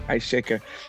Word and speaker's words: Ice Shaker: And today, my guest is none other than Mr. Ice Shaker himself. Did I say that right? Ice Shaker.